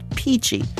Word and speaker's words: Pici: The restaurant was Fresco Italian Cafe, and the dish was a Tuscan pasta called Peachy. That's spelled P-I-C-I.